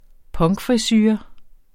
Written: punkfrisure

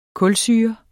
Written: kulsyre

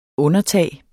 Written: undertag

